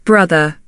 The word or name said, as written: brother